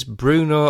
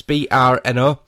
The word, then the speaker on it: Brno: And the track is, I don't know how you pronounce this, Bruno or Brno GP. It's Brno GP track in the Audi R18 LMP1.